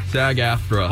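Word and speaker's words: SAG-AFTRA: In other words, was shirtless to sing Taylor Swift's Anti-Hero at the SAGF Frodo fundraiser. I can't pronounce that. – AFTRA. SAG. Just say SAG AFTRA. – SAG AFTRA.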